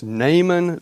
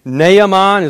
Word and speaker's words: Naaman: Naaman is the way it's pronounced, I think.